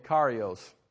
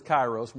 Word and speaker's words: kairos: The preacher talked about kairos last week, and he kept calling it karios. The Greek word is kairos.